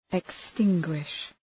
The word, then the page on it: extinguish: ık’stıŋgwıʃ